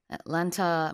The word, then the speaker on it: Atlanta: And pronunciation, usually it's just pronounced Atlanta, right? Instead of Atlanta. – 그렇죠. 이건 한국식 발음이니까. – Yeah. 네. – Atlanta, 뭐 미국식으로 말 Atlanta. 그래서 세 개 음절이에요. 우리는 Atlanta, 사음절로 한국식으로 하지만 영어로는 삼음절.